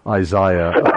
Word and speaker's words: Isaiah: And by the way, I prefer the way you folks in the UK pronounce Isaiah. Isaiah.